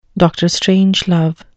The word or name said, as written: dr strangelove